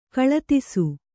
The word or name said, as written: ಕೀಳುದೆಸೆ